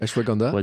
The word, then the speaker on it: Ashwagandha: Ashwagandha.